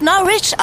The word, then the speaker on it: Norwich: Es wird Norwich ausgesprochen. – Ah. Norwich? Hm. Oh, okay. Das wusste ich gar nicht. Ich auch nicht. Hm. Ja, das geht vielen so. Weil es ja auch Norwich geschrieben wird. Die Aussprache ist dann eher überraschend.